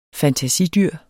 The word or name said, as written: FANTASIDYR